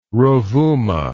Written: Rovuma

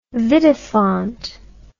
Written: vidifont

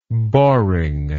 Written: boringness

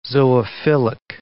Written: zoophilic

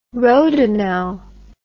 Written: rhodinal

